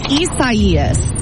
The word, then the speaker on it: Isaias: It's Isaias. It's Isaias. I see, I was saying Isaias.